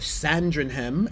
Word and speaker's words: Sandringham: At the Sandringham Estate in Norfolk.